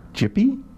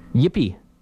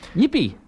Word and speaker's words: Gipi: Oh, I just wanted to mention that the second Ignatz volume by the Italian cartoonist, who we believe is pronounced Jippy? – Yippy. – No, G-I-P-I. – Yippee. – Yippee, yeah.